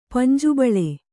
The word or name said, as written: ಪಂಜುಬಳೆ